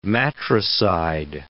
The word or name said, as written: matricide